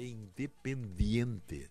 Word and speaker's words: Independiente: A pronúncia correta é Independiente. Sabe por quê? Se você acha que, que, que, que não é, que é difícil, não é. Como é dente. Dente, esse dente de morder em espanhol tu diz diente.